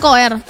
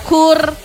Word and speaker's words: Koer: Tapi anyway, ya buat senda tahu cara baca nama fans Koer, betulan Koer, bukan Kor gitu. Atau Kur. Tapi ya Mbak, tulisannya Koer gitu ya.